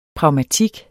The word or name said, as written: pragmatik